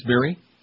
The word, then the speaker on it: Barry: writes Barry. Rhymes with Mary.